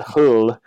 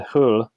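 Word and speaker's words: Hölle: And so, you know, and even in German, the German, and I can't pronounce umlauts because I'm a terrible person, but I think you pronounce it hull. So H-O with an umlaut, L-L-E, just originally meant hill or covered over place.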